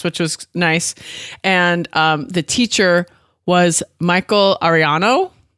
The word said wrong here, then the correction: Arellano: I think I said his name right. I tried to listen twice so I could make sure I said it right. – Yeah. Arellano.